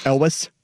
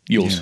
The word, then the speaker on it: Elwes: Elvis? – Elwes. Yeah. I know.